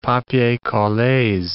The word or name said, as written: papiers colles